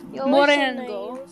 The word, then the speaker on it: morango: Morango.